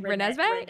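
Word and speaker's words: Renesmee: Is that how you pronounce her name? Renessa May? – Renessa May? Yeah. Renessa May.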